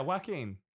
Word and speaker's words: Joaquin: The J is silent. Like, think Juan. That's the way I remember it. – Yeah. – Like, Juan, like Joaquin.